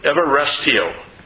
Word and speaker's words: euaresteo: Well, the Greek word here is number 2100, and it's everestio. But it's in the Greek, it's spelled E-U-A-R-E-S-T-E-O, if you want to look it up.